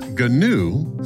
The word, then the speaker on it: GNU: GNU, pronounced GNU, stands for GNU's Not Unix.